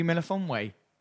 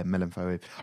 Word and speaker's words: Melifonwe: I completely said his name wrong. – Melitono? – No, it's like Obi Melifonwe. It's like with an F. There's no T.